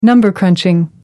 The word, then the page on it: number crunching: American English